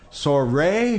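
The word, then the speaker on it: soirée: And it's a special senior soiree. How do we say it? – Soiree. – Yes. Soiree.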